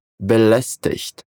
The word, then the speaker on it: belästigt: Como ele termina com IGT, é possível que você ouça sons diferentes dependendo de onde você estiver na Alemanha. O sotaque do Hochdeutsch é belästigt, com esse som chiado, como se fosse um som de CH na palavra ich.